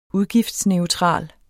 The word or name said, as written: udgiftsneutral